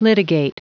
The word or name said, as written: litigate